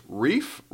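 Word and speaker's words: Reiff: Riley, I think it's pronounced Reef.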